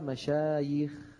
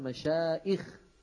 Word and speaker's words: مشايخ: إذن باعتبار عينها حرف علة تجمع على مشايخ، ولا يقال مشايخ، هذا غلط.